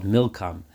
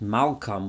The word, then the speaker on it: Milkom: It's referring to the god of the Ammonites, who is the god of what they called Milkom. Now, it could also be pronounced Malkom, which would then mean, why did their king do it? But most certainly it means Milkom, the idol.